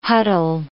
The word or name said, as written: huddle